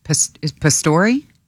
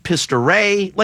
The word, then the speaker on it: Pastore: How do you say his last name? – Pastore? – No, but some people are saying pistoray.